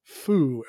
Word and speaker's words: Faux: Now they pronounced it, they spelled it F-A-U-X, but it's pronounced Faux, F-O-E, as in the bad guy. So they want to make a different one. Now, F-A-U-X can really be pronounced foo as well. I feel like some of the other puns in the game still require it to be foo. So when I read through it, I just pronounced it as foo. And in my head, that's been my preference, even though theoretically it's meant to be foe.